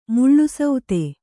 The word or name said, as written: ಮುಳುಸೌತೆ